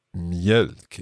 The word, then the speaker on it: mjölk: Mjölk. Is that an O with two dots sound? – Yes, so it's M-J-E-L-K.